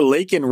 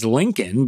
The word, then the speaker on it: Laken: Biden mispronounced Riley's first name as Lincoln, but called her an innocent young woman who was killed by an illegal.